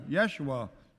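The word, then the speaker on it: Yeshua (ישוע): This name Jesus comes from the Hebrew, Yeshua, also sometimes pronounced Yeshua.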